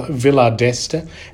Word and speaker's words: Villa d'Este: I have uh, Gregory Bradell from the wonderful Villa d'Este and the Villa La Massa. Is that right? Did I pronounce that right? Absolutely. Fantastic.